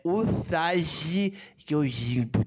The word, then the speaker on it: Usagi Yojimbo: A pronúncia é Usagi Yojimbo, Thiago.